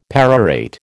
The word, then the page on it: perorate: American English